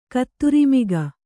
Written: ಕುದುರೆಮುಖ

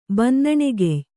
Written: ಬನ್ನಣೆಗೆಯ್

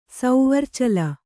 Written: ಸೌವರ್ಚಲ

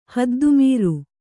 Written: ಹದ್ದುಮೀರು

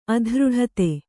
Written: ಅಧೃಢತೆ

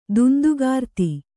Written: ದುಂದುಗಾರ್ತಿ

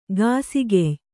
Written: ಗಾಸಿಗೆಯ್

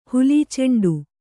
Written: ಹುಲೀಚೆಂಡು

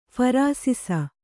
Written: ಫರಾಸಿಸ

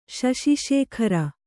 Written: ಸಸಿಸೇಖರ

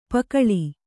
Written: ಪಕಳಿ

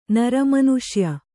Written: ನಿರ್ಮಾನುಷ್ಯ

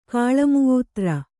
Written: ಕಾಳಮುೂತ್ರ

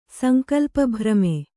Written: ಸಂಕಲ್ಪಭ್ರಮೆ

